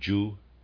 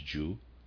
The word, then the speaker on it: Jeu: and then the text about the secret of Jew, and readers aside, I'm pronouncing J-E-U as Jew, and of readers aside.